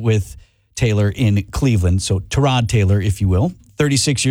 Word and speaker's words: Tyrod: It's correctly pronounced Tyrod, but he doesn't correct people. No, he's awesome guy. – Oh, pardon me then. Tyrod it will be.